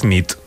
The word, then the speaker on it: nit: Y noche es nit. – Muy bien. – Buena nit. Buena nit, es verdad. Buena nit.